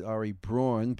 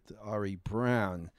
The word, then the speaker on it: Braun: Ari, now it's B-R-A-U-N. I always called it Ari Braun, but I was told he pronounced it Ari Brown.